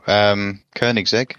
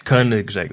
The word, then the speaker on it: Koenigsegg: But then I was more into making the big motor magnets for the Koenigs. Is that the name of? I can never pronounce that car right. – Oh, Koenigsegg. – Koenigsegg, that's it.